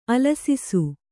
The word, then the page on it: ಅಲಸಿಸು: kannada